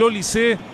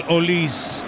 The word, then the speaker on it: Olise: Muy bien, la historia indica que Olise, se hace llamar Olise, pero si utilizara la fonética francesa, debería ser Michael Olise, porque él vivió en Inglaterra desde muy chico, nació en Francia, pero se fue a vivir a Inglaterra y por eso él pronuncia como Olise su apellido, que debería ser en francés Olise.